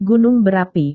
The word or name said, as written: gunung berapi